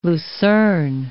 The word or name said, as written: lucern